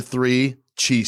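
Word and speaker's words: 3Chi: Three Chi. It's just like how it sounds. It's the number three Chi, C-H-I. It's pronounced Three Chi. Uh-huh. There's not one Chi. It's like a very good pizza. There's three cheese.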